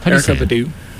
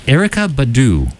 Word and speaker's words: Erykah Badu: Erykah Badu. – Erykah Badu.